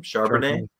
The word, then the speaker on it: Charbonnet: Charbonnet.